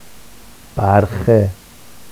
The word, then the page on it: barxe: Vafsi